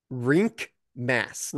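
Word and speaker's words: Rienk Mast: Rink Mast. – Rink? – Like, ice mast. Rink, mast of a ship.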